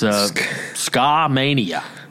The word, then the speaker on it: Skamania: am I pronouncing this correctly, Skamania County, Washington? It's Skamania.